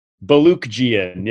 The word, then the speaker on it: Baluchian: I butcher it. Yeah, it's Baluchian. Yeah, just pronounce all the letters. It's all there.